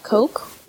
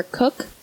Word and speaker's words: Koch: It's K-O-C-H. We've chosen to pronounce it Koch. – But you dug into it. – Yeah, for sure. – Koch. – Well, here's the thing. I don't really know how to say Koch is not how I would express. – I've heard it pronounced Coke or Cook.